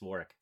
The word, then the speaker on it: Warwick: You don't pronounce the W. It's Warwick.